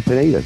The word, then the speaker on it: Pineda: Now I know I called it Pineda last year, but the locals call it Pineda. – Oh, right. – That's how it's spelled. I was trying to out-Spanish them, but they're, of course, not Spanish. They're Catalan. Yeah, and they just say Pineda.